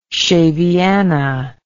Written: shaviana

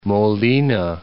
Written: Molina

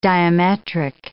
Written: diametric

